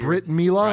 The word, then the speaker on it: Brit Mila: Brit Mila? – Right. – Is that how you pronounce it? – Yeah, that's exactly right.